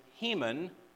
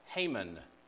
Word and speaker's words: Heman: Now, this is a message in itself, and we can't develop it for sake of time, but there was Asaph, and there was Heman, sometimes pronounced Haman.